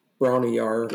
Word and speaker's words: Bron-Y-Aur: Some of them's like it's another blues song that they kind of covered, but um, like Bron-Y-Aur is Bron-Y-Aur.